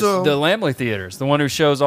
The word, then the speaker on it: Laemmle: By the way, absolutely pronounced Laemmle. I finally took the fucking time to look it up. It is Laemmle.